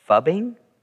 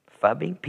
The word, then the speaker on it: phubbing: I don't even know if I pronounce it right. Fubbing? Fubbing? P-H-U-B-B-I-N-G.